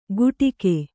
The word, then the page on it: ಗುಟಿಕೆ: kannada